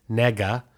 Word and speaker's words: Negga: Her last name is spelled N-E-G-G-A. Nega.